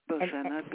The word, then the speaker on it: Bozena: Hi, this is Bojana from New York. – From New York. Okay. Can you spell that? – Hello. – Hello. – Bojana, B-O-Z-E-N-A.